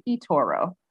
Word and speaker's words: Itoro: Um, so even with the way I pronounce my name, the tonality of it is off. So every time somebody here will ask me my name, I'll be like, it's Itoro.